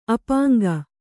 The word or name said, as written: ಅಪಾಂಗ